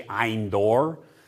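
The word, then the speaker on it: Ein Dor: If you're pronouncing it in Hebrew, it's actually Eindor. Ein is a fountain. Dor is the name of a place. The fountain at Dor. Endor.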